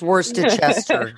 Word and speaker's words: Worcestershire: I think it's Worcestershire. I'm pretty sure that's the proper pronunciation.